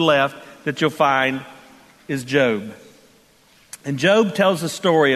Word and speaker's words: Job: If you have your Bibles, turn with me to the book of Job. Yes, it's pronounced Job, not Job.